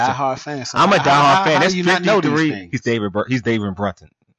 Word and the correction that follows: Bruton: He didn't Bruton. Excuse me, David Bruton. I can't even say his name correctly. David Bruton.